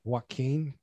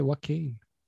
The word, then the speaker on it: Joaquin: Did you call him Joaquin? – Joaquin, that's it. Yeah, I say Joaquin.